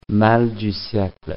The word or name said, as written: mal du siecle